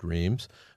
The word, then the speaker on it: Reims: He was with Eisenhower in Reims, which is sometimes pronounced Reims, France, in the Champagne region, which is where the Scheif headquarters was.